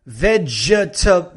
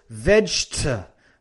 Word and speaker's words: vegetable: so it's not B, no. The V in English is labio dental, entonces it's V. And then la G fuerte, j, veg. Y luego la E ahí en medio, no se pronuncia. Entonces, no es vegetable, no, it's just vegetable.